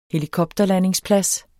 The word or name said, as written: HELIKOPTERLANDINGSPLADS